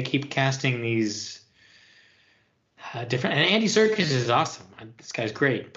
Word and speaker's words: Serkis: Yeah, Andy Serkis.